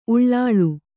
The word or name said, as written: ಉಳ್ಳೊಳ